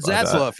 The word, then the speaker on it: Zaslav: It's still not finalized yet, but the new CEO, David Zaslav, is that how you say his name? Zaslav? – David Zaslav, yeah.